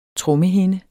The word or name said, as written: trommehinde